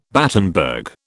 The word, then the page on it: Battenberg: British English